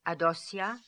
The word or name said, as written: Adossia